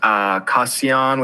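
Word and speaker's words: Cassian: It's Kassian, which is my full name, V13. Just, it's spelled literally C Asian, but not pronounced that way.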